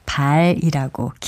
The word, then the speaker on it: 발: 이 경우에는 발이라고 길게 발음합니다. 반면에 사람이나 동물의 다리 맨 끝부분에 있는 발은 짧게 발음해서 발로 차다, 발이 빠르다 같이 말합니다.